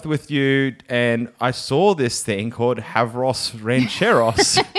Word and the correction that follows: Huevos Rancheros: Huevos Rancheros.